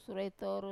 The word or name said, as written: sura e toru